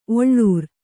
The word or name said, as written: ಒಳ್ಳೂರ್